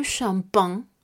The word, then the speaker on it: champagne: Algunos ejemplos con este sonido que lo haremos con la CH juntas será le champagne, le champán, que se escribe el E por el artículo y enseguida C-H-A-M-P-A-G-N-E champagne.